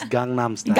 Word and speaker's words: Gangnam: Is that, am I even saying it right? – It's Gangnam Style. – Gangnam. You're Gangnam Korean, style.